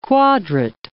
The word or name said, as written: quadrat